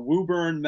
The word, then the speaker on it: Woburn: A neighboring town over, Woburn, Mass. – Woburn, right. Yeah. And now most, spell it, W-O-B-U-R-N, right? – Right, yep.